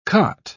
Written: cot

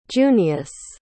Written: junius

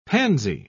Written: pansy

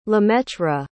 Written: lemaitre

